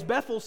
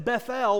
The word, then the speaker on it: Bethel: I've pronounced that two ways. Bethel sounds normal, because if you know a place called Bethel or Bethel Baptist Church, that's how we say it. But a couple times I tried to pronounce it Bethel, which is a little closer to how it might be said in Hebrew.